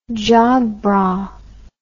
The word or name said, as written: jog bra